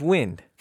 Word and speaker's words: Wynd: And the lead character's name is, oh, it's Wind. – Spelled funky. – Would you look at that? – It's spelled W-Y-N-D.